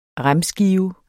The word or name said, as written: remskive